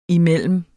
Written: imellem

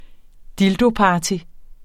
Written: dildoparty

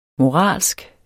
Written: moralsk

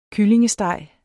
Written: kyllingesteg

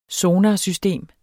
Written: sonarsystem

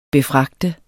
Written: befragte

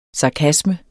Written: SARKASMER

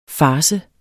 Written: FARCEN

